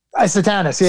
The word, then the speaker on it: Satanus: There was, So DeMatteis, he did this whole thing with the battle for the soul of Metropolis, and he used Lord Satanus. Is that how we're pronouncing it? – Satanus. Yeah, Satanus.